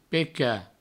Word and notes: pecat: provencal

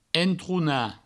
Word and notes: entronar: entrouna; provençal

